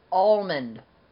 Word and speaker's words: almond: How do you pronounce the word almond? – Almond. Not almond or almond. Almond. Almond. – Like with the M-U-N-D sound.